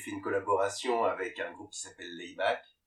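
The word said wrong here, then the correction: Laibach: je sais pas si je le prononce bien. – Oui, Laybar. – Laybar.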